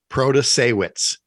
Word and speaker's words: Protasiewicz: On Upfront, they talked to Milwaukee Judge Janet Protasiewicz, and I will add parenthetically, I'm saying it right. We've checked. We've listened to the audio from her, Protasiewicz.